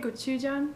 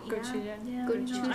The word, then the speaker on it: Gochujang: Gochujang? – Gochujang.